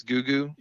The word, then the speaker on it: Gugu: It's like Mugu. – Oh yeah. – It's Gugu. – Yeah. – Gugu is her name.